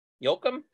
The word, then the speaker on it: Yoakum: Yoakum, Y-O-A-K-U-M. Yoakum?